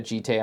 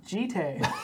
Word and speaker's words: Jitte: Nothing tilts me more than people calling it like Jit or Jeet, I heard once. It's like Kamigawa is a Japanese set. It's pronounced Jitte.